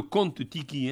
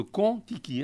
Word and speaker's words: Kon-Tiki: Pas le Kon-Tiki, hein, le Kon-Tiki. Hein. – Ça s'écrit avec un K, con, hein, je précise.